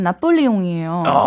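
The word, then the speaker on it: Napoléon: 비슷하지만 약간 피 발음이 약간 쌍비읍 발음이 나거든요. 그래서 나폴레옹이에요.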